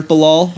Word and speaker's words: Bilal: And in that scene, it's like completely unforgivable, which is a bit of a bummer for Bilal. Or once again, I keep feeling like I'm not saying his name right, but whatever. – No, you're saying exactly right.